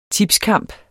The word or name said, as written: tipskamp